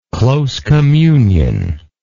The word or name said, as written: close communionist